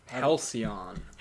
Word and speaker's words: Halcyon: Halcyon. – Halcyon, that's how you pronounce it? Never knew that. – That is the word.